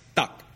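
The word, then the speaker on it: Duck (德): De, which ironically means morality or virtue, is pronounced Duck.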